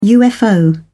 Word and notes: ufo: British English

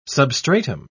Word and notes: substratum: British English

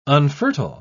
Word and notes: unfertile: British English

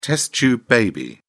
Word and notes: test tube baby: British English

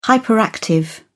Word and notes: hyperactive: British English

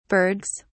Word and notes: bergs: British English

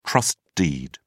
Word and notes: trust deed: British English

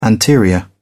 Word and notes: anterior: British English

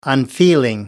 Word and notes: unfeeling: American English

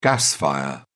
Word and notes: gas fire: British English

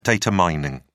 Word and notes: data mining: British English

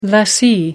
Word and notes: lessee: American English

